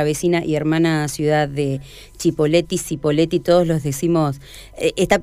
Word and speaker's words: Cipolletti: Porque es argentinizar un apellido italiano, que si se dijera en italiano es Cipolletti, porque la C se pronuncia como CH.